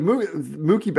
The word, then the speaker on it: Mookie: No, he's saying Mookie now, but he was for a very long time saying Mucky Betts. – Mucky Betts. I mean, we should have just kept him saying that and never told him that he was saying it wrong, because that's way funnier.